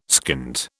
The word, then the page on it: skint: British English